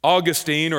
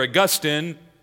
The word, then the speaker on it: Augustine: I've been reading the spiritual autobiography of Augustine, or Augustine, as some would pronounce his name, and he wrote this book called Confessions in the late 300s.